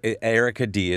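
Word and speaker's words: Erica Dee: So Erica D is spelled Erica, E-R-I-C-A, and then D is D-E-E.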